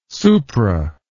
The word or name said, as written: supra